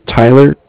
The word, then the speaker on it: Tyler: My name is Tyler, T-Y-L-E-R. – Right. – And I'm calling with Cardiff. It's spelled C-A-R-D-I-F-F, as in Frank Frank.